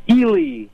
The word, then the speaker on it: Ely: Oh, is that Ely? – Ely, yeah, very good.